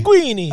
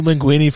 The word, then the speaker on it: linguini: I made linguini for my kids last week, and they kept saying linguini. I'm like, no, you're saying it wrong. You're saying you got to say linguini.